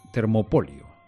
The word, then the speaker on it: termopolio: Las tiendas de comida rápida se llaman Termópilo. A ver si lo digo bien. Termopolio, no Termópilo. Termopolio.